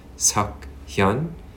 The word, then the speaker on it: Suk-hyun: My middle name is my Korean given name, which is pronounced Suk-hyun. And my last name is my family name, Yoon. – Hyun. Almost like with an H in front of it a little bit.